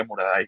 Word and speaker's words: Muraday: el Muradei, pero se pronuncia Muradai, o sea, totalmente castellanizado.